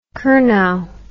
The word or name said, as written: curnow